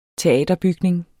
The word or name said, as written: teaterbygning